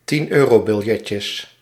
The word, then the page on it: 10 eurobiljetjes: 10 eu·ro·bil·jet·jes